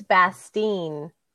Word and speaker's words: Bastien: It's like, actually, it's Bastien.